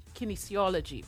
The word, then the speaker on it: kinesiology: Makiba, who holds a Bachelor of Science, let me say that, a Bachelor of Science, people, okay, in How you pronounce it? – Kinesiology. – Kinesiology, right. You know, there's some words that are a little tricky.